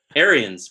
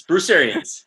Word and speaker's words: Arians: Is it pronounced Arenas or Arians? Isn't it Arians? Bruce Arians.